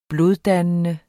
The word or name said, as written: bloddannende